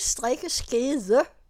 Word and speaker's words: strikkeskede: Der står, at det skrives strikkeskede, men udtales strikkeske.